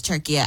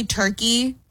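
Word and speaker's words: Türkiye: because I thought it was turkey. No, it was Turkey. – It is Turkey. I think we say turkey, but the correct pronunciation is Turkey.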